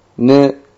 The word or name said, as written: nu